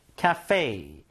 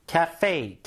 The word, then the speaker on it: cafe: 카페가 아니라 영어 발음은 cafe. cafe. 카에 가깝고 fei. fei.